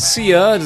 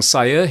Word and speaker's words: Sia: Und so mache ich es jetzt auch bei der australischen Sängerin Sia. Sie spricht sich nämlich so aus und nicht wie viele sagen Sia. Hier ist sie also Sia gemeinsam mit Sean Paul.